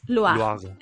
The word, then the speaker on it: Loire: O como se pronuncia en inglés. O en francés. Loire, Efectivamente.